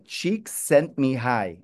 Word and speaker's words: Csikszentmihalyi: And it's like Csikszentmihalyi, and that's how you pronounce it.